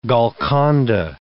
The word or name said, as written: Golconda